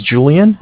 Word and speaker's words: Julian: My name's Julian. It's J-U-L-I-A-N.